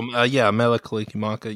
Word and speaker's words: Mele Kalikimaka: And the Bing Crosby, I'm going to butcher this, Mele Kalikimaka. – Oh, Mele Kalikimaka.